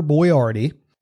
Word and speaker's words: Boiardi: But I think a lot of people probably didn't figure it out because it is spelled so differently than what you're used to hearing. But the man I'm talking about today, his name is Ettore Hector Boiardi, and later in life he would become Chef Boyardee.